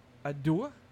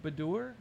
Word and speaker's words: adieu: So we say adieu. How do you say that? Badur? Adore? – Adieu. – Adieu? Bid adieu? – I bid you adieu.